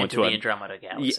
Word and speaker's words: Andromeda: Andromeda.